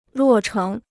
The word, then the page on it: 落成: luò chéng